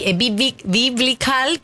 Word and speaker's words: biblical: Lo que hizo este hermano Gallagher fue elogiar, repostear y puso una sola palabra, biblical. Perdón, no se pronuncia así. Biblical, que es bíblico, como algo súper épico.